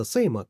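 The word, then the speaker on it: SAMO: Произносится Сейма, как в выражении «same old shit» — «все та же хрень», в надежде привлечь внимание хипстеров из художественной тусовки.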